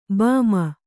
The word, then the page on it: ಭೀಮ: kannada